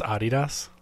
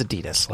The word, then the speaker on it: Adidas: Do you know it's pronounced Adidas? – It's. Okay, it's Adidas.